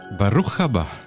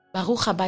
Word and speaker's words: Baruch Haba: Baruch Haba. – Ah, dankjewel. – Is dat juist uitgesproken? Ja. – Baruch Haba is voor een man, tegen een man. – Baruch Haba'a is voor eh, een vrouw.